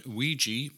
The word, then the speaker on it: Ouija: The word Ouija, pronounced W-E-E-J-E-E, is an ancient Egyptian word meaning good luck.